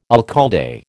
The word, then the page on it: alcalde: American English